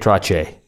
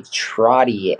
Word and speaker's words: Trottier: That's how the Canadians say it, Trottier. It's that sort of. – I think we say Trottier.